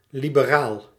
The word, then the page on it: libre: /libʁ/; France